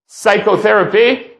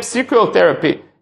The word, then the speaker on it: psychotherapy: If they borrow a word from English, including psychotherapy or any other word that begins with the P, where the P is not pronounced in the English language, yet in Hebrew, they pronounce the P, and they would call that psychotherapy, and they would pronounce the P.